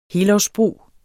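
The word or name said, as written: helårsbrug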